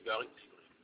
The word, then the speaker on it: Djibril: Gary Djibril. Ça s'écrit comment? – G-A-R-Y Djibril, c'est D-J-I-B-R-I-L. Djibril.